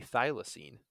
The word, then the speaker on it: thylacine: Also known as the thylacine.